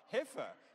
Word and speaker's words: heifer: See, the difference between this story and the New Testament story is that it's not the heifer, oh goodness, I didn't even get it right, heifer. So it's like a silent I in heifer, okay.